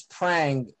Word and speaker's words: Prang: Like my last name is Prang, and for whatever reason, from my whole life, it's been pronounced by people or spelled Pragu, where they like take out the N and put a U after the G or something.